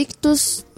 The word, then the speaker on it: Ichthus: Lembrando que Ictus se escreve I-C-H-T-H-U-S. Então tem dois Hs, tá bom?